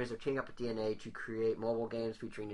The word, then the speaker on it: DeNA: So unless you've been living under a rock, you're probably aware that Nintendo is now going to be teaming up with DNA, spelled D-E-N-A, but pronounced DNA, to create...